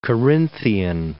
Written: Carinthian

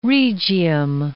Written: Rhegium

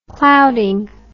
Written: clouding

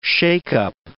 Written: shake-up

